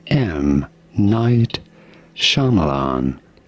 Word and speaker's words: Shyamalan: Say M. Night Shyamalan.